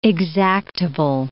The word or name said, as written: exactable